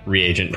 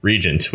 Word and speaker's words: reagent: You know, I'm had this revelation that's basically, wow, yeah, reagent probably really is the way you pronounce that, because yeah, that makes a whole lot more sense than the way I always thought it was, which is just regent, which is totally wrong.